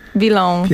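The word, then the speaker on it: Bilhão: A uwaga, uwaga, pisze się B i z kropką L, H, A, O. Ale wymawia się... – Bilon.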